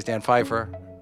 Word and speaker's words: Pfeiffer: My guest today is Dan Pfeiffer. Am I pronouncing that correct? – You are correct, yes. Uh,